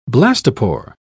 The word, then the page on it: blastopore: British English